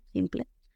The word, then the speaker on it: simple: Simple se escribe igual, pero se pronuncia simple, simple. ¿Ven lo que les digo en la P? La P es la protagonista de hoy. Simple, simple, sim y luego pel. Lo que ocurre aquí es la PLE,